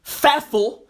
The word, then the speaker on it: Pfeffel: The P is fucking silent. So technically, his name is Alexander Boris the Pfeffel.